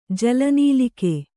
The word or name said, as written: ಜಲನೀಲಿಕೆ